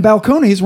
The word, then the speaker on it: Balcones: And Balcones rhymes with cojones.